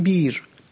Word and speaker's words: بئر: گاهی در میانه های کلمه، کلمه بئر به معنای چاه، اون حرف میانی همزه است، گفتم بیر، این رو با یا تلفظ کنید که تلفظ راحت تری و مقتصدانه تری باشد و آسودگی بیان و زبان در اون تضمین شده باشد و تأمین شده باشد.